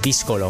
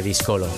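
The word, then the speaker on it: Díscolo: yo supongo que el trabajo de Ángel Heredia debería ser Discolo más que Discolo.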